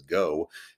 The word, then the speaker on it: GHO: GHO, pronounced as Go,